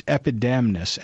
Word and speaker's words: Epidamnus: And would you pronounce that again for us? – Sure, it's Epidamnus, and that is simply the name of the city where the play takes place.